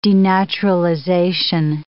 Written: denaturalization

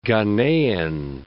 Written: Ghanaian

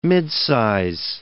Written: midsize

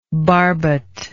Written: barbut

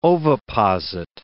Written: oviposit

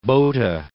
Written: Botha

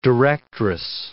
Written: directress

